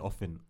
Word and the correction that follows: Oppenheimer: Oppen. – Is it Oppenheimer? That's how you say it.